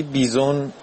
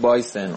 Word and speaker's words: بایسن: یه پیغام دارم واسه فرید، تلفظا رو درست کن آقا فرید، اون چیزی که تلفظ کردی بیزون غلطه، اصلش هست بایسن، اون بوفالویی که تعریف کردی تو جاده ای تو آلاسکا بوده و پلیس براش موزیک پخش کرده، تلفظ صحیحش هست بایسن.